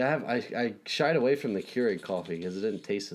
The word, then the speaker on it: Keurig: Keurig.